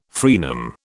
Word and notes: fraenum: British English

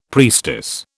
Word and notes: priestess: British English

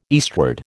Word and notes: eastward: American English

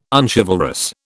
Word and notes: unchivalrous: British English

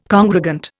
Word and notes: congregant: Indian

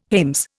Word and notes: hames: Indian

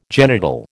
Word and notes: genital: American English

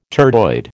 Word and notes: turdoid: American English

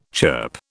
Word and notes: chirp: British English

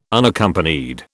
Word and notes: Unaccompanied: British English

